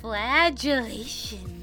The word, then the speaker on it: flagellation: Flagellation.